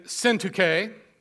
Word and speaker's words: Syntyche: I entreat Euodia and Syntyche to agree in the Lord.